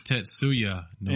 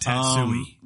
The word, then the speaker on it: Tetsuya Nomura: And it's Tetsuya Nomura. – Yeah, it's a word. – Not Tetsui.